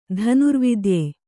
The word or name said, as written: ಧನುರ್ವಿದ್ಯೆ